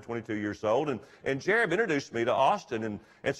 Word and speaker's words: Jarib: His name is Jarib Burnett. It's spelled J-A-R-I-B. It's a little hard for me to say, but Jarib Burnett.